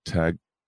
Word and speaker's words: Tagalog: Tagalog.